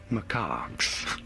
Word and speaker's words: macaques: Macaques. Macaques. Pretty straightforward. Once you know, you do want to stress on the second syllable.